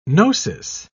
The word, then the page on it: gnosis: British English